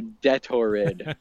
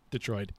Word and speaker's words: Detroit: Is it Detroit or Detorid? – Detroit. Like Detroit. – No, look at it. Look how it's spelt. – I know. – Detorid. I actually had people agree with me on pronunciation last episode. – Oh, good, good. Those people are wrong.